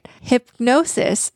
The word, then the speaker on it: Hipgnosis: Early in 2021, Shakira sold the rights to her catalog of songs to the Hypnosis Song Fund. – And it's pronounced hip-hyphen-gnosis. – No, it's actually, it's spelled very strange. H-I-P-G-N-O-S-I-S.